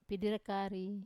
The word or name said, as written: piderekari